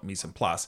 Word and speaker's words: mise en place: Say this correctly for me. Mise en place. – Yes, that is correct.